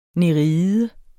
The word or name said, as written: nereide